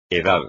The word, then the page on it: edad: espagnol